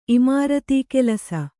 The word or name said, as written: ಇಮಾರತೀಕೆಲಸ